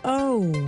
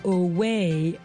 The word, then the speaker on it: owe: Ça s'appelle O-W-E. O-W-E. Et contrairement à la façon dont ça s'écrit O-W-E, O-W-E, on penserait peut-être dire away ou oh-oui. Non, ça se dit oh. Et en fait, ça s'entend comme si on parlait de la lettre O.